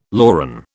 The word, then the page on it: loran: British English